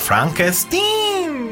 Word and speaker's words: Frankenstein: Y de hecho lo estoy pronunciando como lo pronuncian en la película, Frankenstein este que es famosísima película de Mel Brooks, también hizo Blazing Saddles o él también en, Oeste.